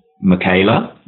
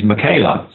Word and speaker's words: Michaela: I started using Michaela, which originally I was pronouncing Michaela. I put an A on the end of Michael.